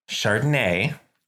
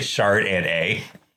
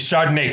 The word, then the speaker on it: Chardonnay: Did you say chard and a? – And nay. Excuse me. It's pronounced nay.